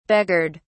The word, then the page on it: beggared: British English